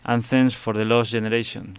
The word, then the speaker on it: Anthems for the Lost Generation: Tengo preparado uno que se titula Twilight, uh-huh, y que también estará incluida en el próximo disco de Love Division, Anthems for the Lost Generation.